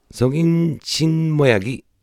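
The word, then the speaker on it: 성인식 모양이: というのを韓国語では、はい、成人 날 은 매 년 각 지에 서 행 해지 는 성 인 신 모 양이 ニュースが 됩니 다。はい、今 성 인 신 모 양 ゆっくり言いましたけど、これは各文字と発音がちょっと違うんでゆっくり言ってみました。本当は 성 인 식 모 양 이 なんですが、発音上 성 인 신 모 양 이 となります。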